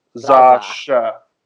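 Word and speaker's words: X Æ A: So, like, the letter X has the, you know, the kind of Z sound, like in xylophone, and then AE, that AE sign, is actually pronounced Ash, and then you've got the A at the end. So if you put it together, Zasha.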